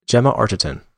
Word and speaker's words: Gemma Arterton: The film stars Jeremy Renner and, how do you pronounce her name, Gemma Arterton? – Gemma Arterton.